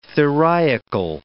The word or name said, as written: theriacal